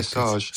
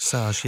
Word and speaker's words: Sage: Pierre Sage? Sage? – Yeah, I think you got it nailed on the first time. Okay, Pierre Sage. – Sage, yeah.